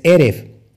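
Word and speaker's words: Eref (ערב): Entonces, la palabra tarde en hebreo es Eref. Así se escribe como lo estás viendo, es Eref. Y fíjate, se escribe así, Ajin, Reish y Bet.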